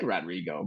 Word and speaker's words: Rodrigo: It's Rodrigo, but you got to say it like this. – Rodrigo! – Like you're yelling down the street to ask him if he wants to play ball or something.